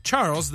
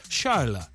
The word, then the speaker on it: Charles: But of course, they won't say Charles. They'll pronounce it Charles.